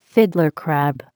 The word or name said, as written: fiddler crab